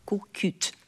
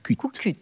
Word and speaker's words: Kukut: Jak wymawiałaś kukułkę w dialekcie auwerniackim? – Kukut. – Kukut. – I to te się wymawia, tak? Na końcu Lukut. Słowa. Tak. – Wymawia się, wymawia się.